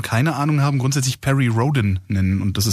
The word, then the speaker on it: Rhodan: Also bisher haben immer, wenn Perry Rhodan-Fans mit mir gesprochen haben, gesagt, ich würde es falsch aussprechen, weil ich immer Rhodan sage. Also scheint Rhodan richtig zu sein.